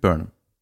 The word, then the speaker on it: Burnham: Burnham? Ja. Heißt wirklich Burnham.